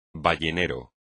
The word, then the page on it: ballenero: espagnol